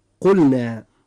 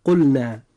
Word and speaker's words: قلنا: نجد أن اللام في قلنا جاء بعدها حرف النون، وحرف النون هذا ليس من حرفي اللام والراء، فيجب في هذه الحالة أن نظهر اللام عند نطقنا لكلمة قلنا، فنقول قلنا.